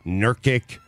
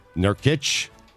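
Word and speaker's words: Nurkić: You've never said his last name right. – How do you say it? – There's an itch at the end. – Nurkic? – Yeah.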